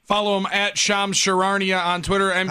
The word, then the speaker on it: Charania: It's Charania, man.